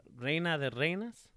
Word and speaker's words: Reina de Reinas: Try it again. – Reina de Reinas? – Yes. – Championship? – Yeah. – I said it right. You yeah. – see? – No, you're good.